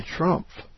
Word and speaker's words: Triomphe: And when I think of the word arch, I think of the famous arch in Paris called De Triomphe. I didn't pronounce that very good. She says pronounced De Triomphe. Triomphe. Okay. To an untrained ear, like mine for French, the famous arch sounds like the Trump.